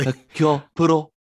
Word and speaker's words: Hercule Poirot: So our main character, Hercule Poirot, I don't know if I say that correctly. – Hercule Poirot. – Everyone's accent is different.